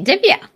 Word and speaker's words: Divya: You pronounced it very beautifully, Divya.